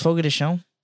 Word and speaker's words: Fogo de Chão: Fogo, you know it's pronounced Fogo de Chão? – Fogo de Chão.